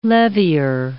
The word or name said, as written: levier